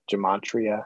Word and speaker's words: gematria: And so, yeah, like people, I think you got to be careful with, is it called gematria? Is that how you say it? – That's what it's called.